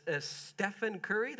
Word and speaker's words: Stephen Curry: And that's Stephen Curry.